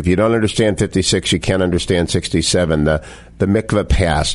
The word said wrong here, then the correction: Mitla Pass: Did I say that right? – Mitla Pass.